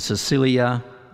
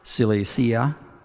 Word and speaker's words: Cilicia: Verse 21, afterward I went to the regions of Syria and Cilicia. I don't think I said that right. Cilicia? Yeah, that's correct. That's better.